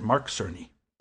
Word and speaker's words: Cerny: Is that how you pronounce that? – I pronounce it that way. I don't know if he does, but we will pronounce it Mark Cerny.